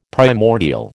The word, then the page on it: primordial: American English